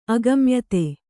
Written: ಅಗಮ್ಯತೆ

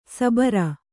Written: ಸಬರ

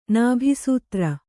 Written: ನಾಭಿಸೂತ್ರ

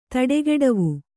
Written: ತಡೆಗೆಡವು

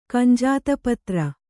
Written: ಕಂಜಾತಪತ್ರ

